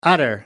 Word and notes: utter: American English

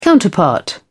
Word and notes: counterpart: British English

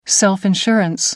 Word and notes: self-insurance: American English

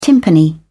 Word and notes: timpani: British English

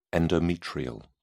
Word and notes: endometrial: British English

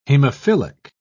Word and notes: hemophilic: British English

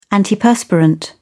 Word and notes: antiperspirant: British English

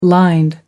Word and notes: lined: American English

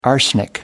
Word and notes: arsenic: American English

